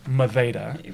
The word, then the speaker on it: Madeira: So it's pronounced Maveda. – Right. – So D's are softened. You mm-hmm. roll the R's, and mm-hmm. then an EI makes a diphthong and a hard A sound.